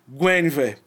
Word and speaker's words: Guinevere: Que eu fiquei sabendo também aí nos estudos que é assim que se pronuncia Guinevere é o nome da pantera.